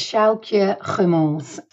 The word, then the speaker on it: Sjoukje Gummels: So, my name is Sjoukje Gummels.